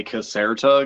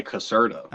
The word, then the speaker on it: Caserta: Well, to be honest, it actually sounds sweeter when you say caserta because it's Italian, but I usually just say caserta.